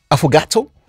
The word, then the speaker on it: affogato: Am I saying this right? Affogato? Affogato? – Yeah. Yeah, pretty similar.